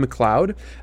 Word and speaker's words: MacLeod: Most often from a composer named Kevin MacLeod. That's how I've seen it. That's how I've heard it pronounced before. MacLeod.